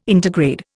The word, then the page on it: intergrade: Indian